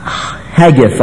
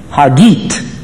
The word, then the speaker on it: Haggith: And she pronounced her name how? Haggit. So, I want you to know that is the right Israeli pronunciation of that name.